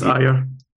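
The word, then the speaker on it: Ajer: It's pronounced Ayer.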